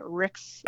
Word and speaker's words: Rixe: Rixe, say it, say it, say it with a French accent. That's how it's said.